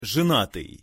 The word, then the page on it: zenatyj: Russian